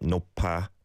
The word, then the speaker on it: 높아: You kind of paused on the 높 there. And I guess, yeah, with the 피읍받침 and the 이응 on the next syllable, they do blend like that, right? 높아, 높아, rather than 높. That's not how we say it.